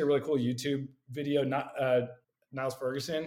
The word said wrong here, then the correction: Niall Ferguson: Niall Ferguson.